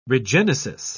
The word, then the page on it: regenesis: British English